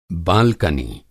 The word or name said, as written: बालकनी